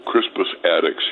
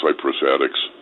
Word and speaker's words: Crispus Attucks: Crispus Attucks, he pronounced Cyprus Attucks in talking about the Boston Massacre.